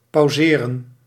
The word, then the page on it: pause: /pɔːz/; /pɔz/; /pɑz/